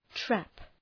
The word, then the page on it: trap: træp